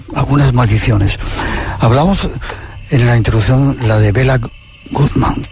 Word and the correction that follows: Béla Guttmann: ¿Quién era, no sé si se dice así, quién era este personaje? – Pues Béla Guttmann fue un entrenador que nació en el año 1899 en Budapest.